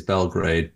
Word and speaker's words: Belgrade: There's a few, I mean, I guess the anglicized way is Belgrade, but in Serbian it's Beograd.